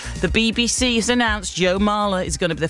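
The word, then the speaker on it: Marler: His name will be Mahler. Rhymes with trawler.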